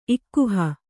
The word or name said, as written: ಇಕ್ಕುಹ